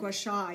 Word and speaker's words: gua sha: Gua Sha? Is that how you say it? – Gua Sha, yeah.